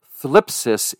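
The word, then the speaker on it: thlipsis (θλῖψις): known as the thlipsis is the pronunciation of the word in the Greek, meaning the pressure.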